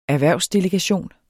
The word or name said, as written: erhvervsdelegation